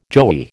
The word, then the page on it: joey: American English